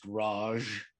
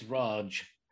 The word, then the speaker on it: Raj: Um, but apart from that, it wasn't written down until the British, um, the Raj, which incidentally is not pronounced Raj, it's Raj. And uh, they were the, uh, uh, the British rulers of India.